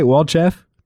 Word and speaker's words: Walchef: Did I say that right, Walchef? – You did. Very well done.